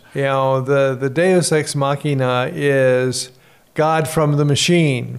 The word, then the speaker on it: Deus ex machina: This is the kind of the opposite, and I probably butcher if this is Latin, the concept of Deus ex machina. – Oh, Deus ex machina? – Told you I'd butcher it.